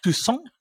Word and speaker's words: Toussaint: And I do want to call out Stephen Toussaint, I believe, is the correct pronunciation.